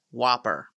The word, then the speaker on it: WOPR: He suggests taking out human intervention and introduces government agents to the War Operations Plan Response Computer, also known as WOPR, pronounced Whopper.